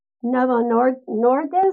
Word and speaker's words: Novo Nordisk: Demetrius has worked with Fortune 500 companies like Dow Chemical, Pfizer, DuPont, Novo Nordisk, is that correct?